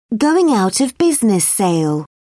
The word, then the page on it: going-out-of-business sale: British English